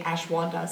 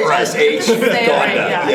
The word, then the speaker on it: ashwagandha: Okay, I'm very phonetic, so you're not saying ashwanda, so I don't know what you're talking about. – It's whatever it's called. That's not how it's spelled. – Yeah. – Say ashwagandha.